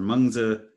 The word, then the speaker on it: Mengzi: Well, so uh, Mencius or Mengzi in the in the newer pinyin pronunciation